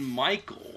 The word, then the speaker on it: Mikl: Mickle? Is it John Michael or John Mickle? – I think it's John Michael. – Because it's spelled M-I-K-L, which is definitely how anybody has ever spelled Michael.